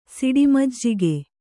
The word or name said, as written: ಸಿಡಿಮಜ್ಜಿಗೆ